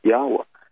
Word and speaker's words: Yahweh: YHWH was the four Hebrew consonant letters, Yod, He, Wah, He, YHWH, which is pronounced Yahweh.